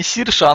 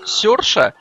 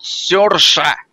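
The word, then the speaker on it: Saoirse: Оказалось, что на самом деле ее зовут Серша.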